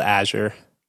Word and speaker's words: Azure: No, it's Azure. Totally Azure. It's Azure. – Azure. – I had a Maple Story, right? – Syrup. – That shitty ass MMO. I had a guild called Azure.